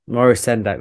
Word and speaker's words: Maurice Sendak: Maurice Sendak, that's it.